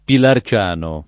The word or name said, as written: Pilarciano